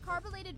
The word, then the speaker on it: carbolated: DL, I heard you mispronounced carbolated Vaseline, and you ended up saying carbonated Vaseline, and swore you were saying it right.